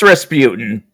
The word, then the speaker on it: Rasputin: So do you, the guy who opens the portal, do you know how to pronounce his name, his character? – Rasputin? – Okay, that's Rasputin. Okay, he's got some, like, weird, but yeah, for the sake of it, yes, his name is Rasputin.